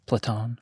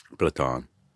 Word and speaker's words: Platon: how do you say it? Platon. Platon. Yeah.